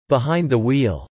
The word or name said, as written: behind the wheel